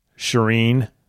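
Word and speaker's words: Shirin: shireen we I was listening to Lucinda Brand pronounce Van Anrooij's name. We've been into Sheeran, you know, cheering, cheering on Sheeran, and then she said Shireen Van Anrooij, and I was like, oh, that's another one I think we've been doing wrong.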